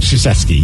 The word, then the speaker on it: Krzyzewski: How do you say his name? Krzyzewski? – Mike Krzyzewski, yes.